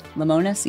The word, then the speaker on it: Lemonis: Is that how you say his name? – Lemonis, yeah.